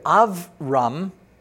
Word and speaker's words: Avram: So if we say Avram, Avraham, as we would say it in Hebrew, we pronounce that as a soft B, and a soft B is a V or a V sound.